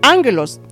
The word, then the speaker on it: ángelos: Y dice que la palabra ángel viene de la palabra griega angelos, que se pronuncia ángelos.